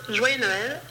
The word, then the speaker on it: Joyeux Noël: Today we're going to learn how to pronounce Joyeux Noël in French.